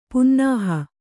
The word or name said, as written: ಪುನ್ನಾಹ